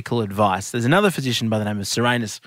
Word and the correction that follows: Soranus: It's actually more like Soranus, and I think we really shouldn't go there talking about ancient Greece. Let's say Soranus.